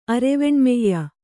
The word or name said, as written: ಅರೆವೆಣ್ಮೆಯ್ಯ